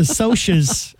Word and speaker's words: Socs: About two gangs, the Sox and the Greasers. Uh-huh. Okay. – It's the Socs and the Greasers. – It's spelled S-O-C. – They call it the Socs, though.